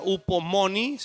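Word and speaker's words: upomoni (ὑπομονή): Es una palabra en griego otra vez que se pronuncia upomoni, se escribe hipomeno, una cosa así rara.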